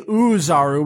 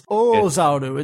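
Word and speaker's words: Oozaru: You said this was sort of close, but they didn't say Uzaru, which is great because that's not how it's supposed to be said. It's Ozaru. It's a long extended O sound at the beginning.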